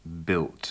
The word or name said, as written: built